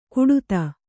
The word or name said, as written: ಕುಡುತ